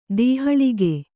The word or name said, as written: ದೋಹಳಗೈ